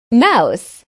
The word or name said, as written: mouse